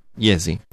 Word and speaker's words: Jesi: You're from a little town called, I don't want to mispronounce it now, Jesi? – Jesi.